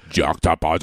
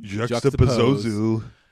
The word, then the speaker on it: juxtapose: Juxtapose. – Juxtapose. – It's three syllables. – Juxtapose.